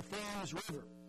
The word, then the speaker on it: Thames: Now, it's spelled the same way as the Thames that flows through London, but in Connecticut, they pronounce it phonetically, T-H-A-M-E-S, the Thames River.